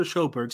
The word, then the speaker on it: Sjoberg: Steve is going to look at Buster Sjoberg, and you see where this ball is just inside yeah. the D. And this is yes, you're not you're saying this correctly. This is Buster Sjoberg, Syracuse's center back.